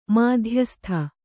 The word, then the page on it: ಮಧ್ಯಸ್ತ: madhyasta; Kannada